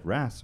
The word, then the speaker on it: wrasse: The Slippery Dick is a species of wrasse. Is that how you say it? It's like a kind of fish. Yeah, wrasse, all right.